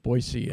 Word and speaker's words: Boise: Yeah, that's Bishop Soto, of course, Bishop Wiegand, of course, is from Idaho, and the Diocese of Boise, and they make sure it's spelled with an S and it's pronounced with an S.